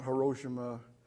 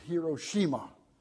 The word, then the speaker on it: Hiroshima: And I was over there, and it's not Hiroshima, it's Hiroshima. That's the way they pronounce it over there.